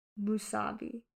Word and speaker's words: musubi: I want to keep saying musabi, and I know it's not right. Musubi. It just takes a while.